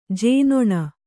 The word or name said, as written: ಜೇನೊಣ